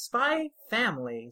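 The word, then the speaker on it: SPY×FAMILY: It's more of a fun comedy, and that's Spy Family. Spy X Family, but I'm sure the X is silent, like in Hunter x Hunter.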